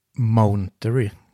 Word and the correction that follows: Monterey: Jag vet inte hur det uttalas. Monterey.